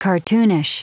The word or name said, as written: cartoonish